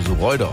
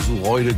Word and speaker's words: Suroide: Ich wusste ja vorhin nicht, dass Sureude Sureude ausgesprochen wird. Sie schreibt, unser Dorf wird tatsächlich Suroide geschrieben, aber Sureude gesprochen.